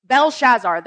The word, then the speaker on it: Belshazzar: Belshazzar, there it is. It was close.